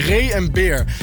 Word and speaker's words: Ray en Beer: Ik wil het alweer op zijn Engels uitspreken. Nee, Ray en Beer.